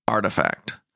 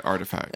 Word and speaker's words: RTFKT: Yeah, so it's RTFKT, and it's pronounced artifact. – Okay, artifact.